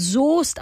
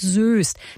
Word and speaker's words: Soest: Der Ort Soest in NRW wird Soest ausgesprochen, nicht Soest.